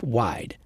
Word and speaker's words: WYD: They call their app Wide. – It's spelled W Y D.